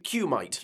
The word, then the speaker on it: Kumite: Okay, it's spelled K-U-M-I-T-E. Kumite. – Kumite, yeah.